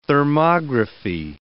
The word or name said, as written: thermography